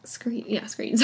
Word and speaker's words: Screeton: According to Screeton, he says that... Screeton, sorry. I was like, have I been saying this wrong the entire time?